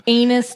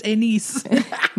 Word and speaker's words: Aenys: Aenys Targaryen. That's like one of the kings at one point. – It's pronounced Aenys.